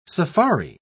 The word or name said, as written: safari